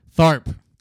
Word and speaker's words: Tharp: Tharp. T-H-A-R-P.